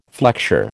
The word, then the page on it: flexure: American English